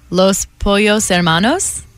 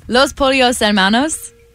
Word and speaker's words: Los Pollos Hermanos: The fast food fried chicken restaurant in the TV series called, I'm going to butcher this, Los Pollos Hermanos. – That was really good.